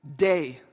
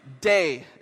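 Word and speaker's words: Day (δεῖ): Day. Day is how it's pronounced, and it means it is necessary, or as they put it here, had to be.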